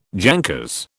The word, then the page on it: jankers: British English